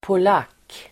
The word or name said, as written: polack